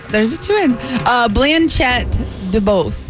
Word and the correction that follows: DuBois: Dubois. – Why don't they pronounce the S's? – It's over French.